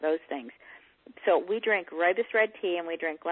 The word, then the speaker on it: rooibos: Our other drink of choice at my home is rooibos red tea, and it sounds like the man's name Roy and the school bus, Roy Bus. But it's from South Africa. It's spelled R-O-O-I-B-O-S, not flavored, just the rooibos.